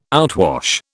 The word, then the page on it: outwash: British English